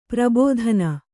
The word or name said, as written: ಪ್ರಬೋಧನ